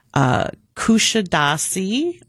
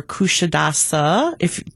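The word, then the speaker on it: Kuşadası: So we are starting in Athens, and we are doing Kushadasi, or Kushadasa, depending if you pronounce the Turkish way or the American way, Kushadasi, they say as well,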